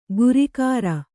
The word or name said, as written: ಗುರಿಗಾರ